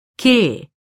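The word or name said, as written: 깨다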